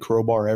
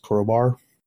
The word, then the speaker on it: Krobar: Crowbar has been a nickname I've had since 1985, I believe, because it sounds similar to my last name. And I adjusted the spelling to make it match my last name. So it's that's why it's spelled K-R-O-B-A-R.